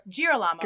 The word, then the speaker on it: Girolamo: G-I-R-O-L-A-M-O. Girolamo? – Girolamo? – Girolamo?